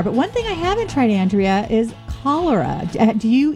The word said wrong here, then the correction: chlorella: Well, first of all, I'm going to correct the pronunciation. It's chlorella is how you pronounce it.